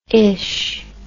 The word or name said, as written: ish